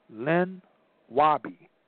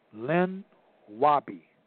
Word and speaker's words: Lenape: The name Tammany comes from a Native American leader of the Lenape, or Lenape, pronounced Len Wobby.